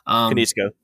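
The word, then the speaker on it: Konietzko: Kanitsko, I think. – I think so. – Yeah. I'm probably butchering it. – Kanitsko.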